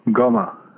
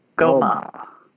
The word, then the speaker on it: Ngoma: N-G-O-M-A. And she's in Texas. – Do you pronounce it Guma? – Goma. – Goma. – Yeah. – Goma. Okay. All right.